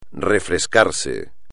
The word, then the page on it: refrescarse: espagnol